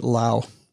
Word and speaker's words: Laos: When I was in Southeast Asia, they were pronouncing it Lao instead of Laos. – Okay. I use all the letters. – I'd always pronounce it. I use all the letters. – Yes. – If they wanted it to be Lao, then they would say L-A-O. They would just. – Yes. – L-O-W.